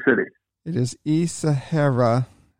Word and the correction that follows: Ishihara: I probably said it wrong. Isahara, uh, Japan. – Ishihara. – Ishihara. Ishihara. Yeah. – Ishihara, I think is how it's pronounced.